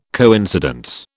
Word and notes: coincidence: British English

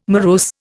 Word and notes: morose: Indian

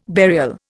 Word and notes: burial: Indian

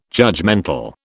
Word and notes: judgemental: British English

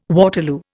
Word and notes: Waterloo: Indian

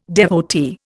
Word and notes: devotee: Indian

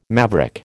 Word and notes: maverick: American English

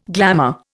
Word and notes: glamour: Indian

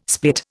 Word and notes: split: Indian

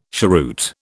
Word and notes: cheroot: British English

